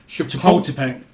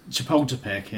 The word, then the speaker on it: Chapultepec: Chapultepec. Chapultepec.